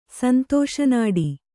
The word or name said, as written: ಸಂತೋಷನಾಡಿ